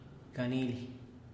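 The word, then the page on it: ganili: Teotitlán del Valle Zapotec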